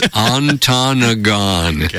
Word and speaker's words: Ontonagon: They even spelt it phonetically, and it's Ontonagon.